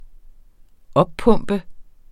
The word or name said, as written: oppumpe